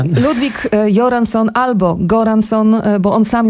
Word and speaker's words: Göransson: No i właśnie autor muzyki, czyli Ludwig, powiedziałaś Göransson. – Ja powiedziałam Göransson, bo jakoś z dawien dawna jestem przyzwyczajona do tego, że szwedzkie G na początku czyta się jak I.